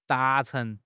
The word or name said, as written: 达成